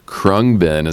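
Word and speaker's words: Khruangbin: All right, that was Krungbin. Look at me, I just learned something new. Krungbin is how you pronounce that.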